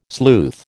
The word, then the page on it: sleuth: American English